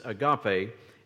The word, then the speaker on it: agape: The Greek word here that the New Testament was translated from is the word agape, kind of an unusual word for an English speaker, A-G-A-P-E. It is pronounced agape.